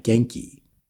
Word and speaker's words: Genki: And Jason Reynolds figured out like, no, it's Japanese. G-E-N-K-I, Genki. – Oh, so it is pronounced Genki because that's always a debate. – Yeah. So Bendis is always, no, it's Genki. But then like G-A-N-K, like that's, there's actually a whole thing in the novel about names.